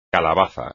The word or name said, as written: calabaza